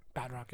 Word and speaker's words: Batroc: But the fight scenes, that fight scene with him and Batroc, am I saying his name right? – Batroc, yeah.